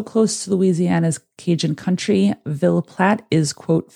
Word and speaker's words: Ville Platte: I tried to find it on YouTube to see if I'm saying it right, but it's V-I-L-L-E-P-L-A-T-T-E, thinking those E's are silent, so we're going to say Ville Platte.